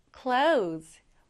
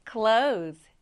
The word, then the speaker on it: clothes: Clothes. 옷이에요, 옷. 여기서 이 th는 거의 발음되지 않는다고 생각하시면 돼요. 그래서 clothes. Close. 네, 이렇게 발음해 주시면 됩니다.